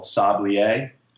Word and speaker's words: Sablier: This new watch company that's built in Princeton called Sablier. It's spelled S-A-B-L-I-E-R, okay.